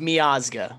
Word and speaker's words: Miazga: Yeah, and I know – I think it's – yeah, it's pronounced Miazga. But Miazga, he's playing in Belgium now and that for Anderlecht, and that's a good team.